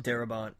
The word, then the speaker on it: Darabont: Darabont? – Darabont, yeah.